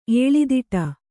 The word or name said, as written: ಎಳೆದಾಡು